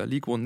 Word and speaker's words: Ligue: League One. – Die französische Liga, Ligue 1, nicht Ligue 1.